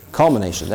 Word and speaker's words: culmination: Culmination, there, that's what it is. I wanted to put an extra syllable in there somewhere.